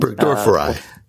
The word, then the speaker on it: Burgdorferi: That seems to be the consensus pronunciation. Actually, one of the researchers yesterday worked with, I think his name was Billy Burgdorferi, and so he pronounced it that way.